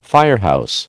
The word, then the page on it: firehouse: American English